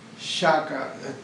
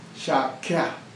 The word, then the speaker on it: Shakya: Shaka, probably you heard about it. Shaka. We don't pronounce Shaka, but they, in northern India, they pronounce Shakya, Shakya, Shakyamuni Buddha.